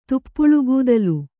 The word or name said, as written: ತುಪ್ಪುಳುಗೂದಲು